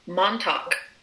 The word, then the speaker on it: Montauk: How did you say that? – Montauk. Hard seltzer. Montauk. – Talk. – You have to say it like Billy Joel.